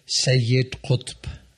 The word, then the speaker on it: Sayyid Qutb: How come we don't hear about Sayyid Qutb? Am I saying that right? It sounds much better when you say it. Say it again. – Sayyid Qutb.